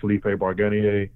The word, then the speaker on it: Felipe Barganier: You can also follow me on YouTube, Felipe Barganier. My name is spelled F-E-L-I-P-E. B-A-R-G-A-N-I-E-R.